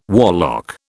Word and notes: warlock: British English

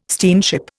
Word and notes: steamship: Indian

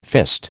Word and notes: fist: American English

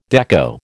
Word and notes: dekko: American English

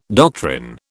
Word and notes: doctrine: British English

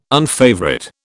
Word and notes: unfavourite: British English

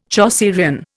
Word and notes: Chaucerian: Indian

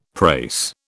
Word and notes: prase: British English

